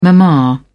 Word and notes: mama: British English

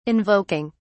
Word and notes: invoking: British English

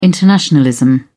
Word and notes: internationalism: British English

